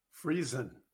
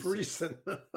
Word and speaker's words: Friesen: Friesen, like Friesen. Friesen.